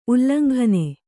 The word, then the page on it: ಉಲ್ಲಂಘನೆ: ullaŋghane